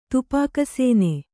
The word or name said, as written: ತುಪಾಕಸೇನೆ